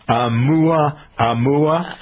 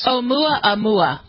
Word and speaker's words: Oumuamua: Oumuamua. – Hold on, let's go. Ready? – I think it's probably. – I love all the Hawaiian names. – Oumuamua. – Oumuamua.